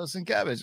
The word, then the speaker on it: Sienkiewicz: It's got to be last week's Sinkiewicz. – Oh, you're still not saying it right. – Sienkiewicz. Sienkiewicz. I got it. – There you go. Sienkiewicz.